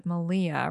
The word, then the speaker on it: Malia: They were Joanna of Knitspin Farm, the Fat Squirrel of The Fat Squirrel Speaks, and Malia, rhymes with Maria, of the Yarn Raising Podcast.